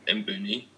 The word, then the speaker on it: Mbuni: but what they called Mbuni or Buni coffee, which is spelled M-B-U-N-I for people who want to look that up.